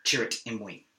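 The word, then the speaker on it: Chirrut Îmwe: Chirrut Imwe. – He's, for those that want to know the correct way to say it.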